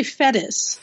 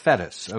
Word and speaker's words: Fettes: Am I pronouncing that correctly? – Yeah, that's actually Fettes. – Fettes, okay.